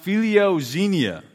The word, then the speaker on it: philoxenia: However, hospitality in the Greek is pronounced philoxenia, and this is the word that is used in the Bible.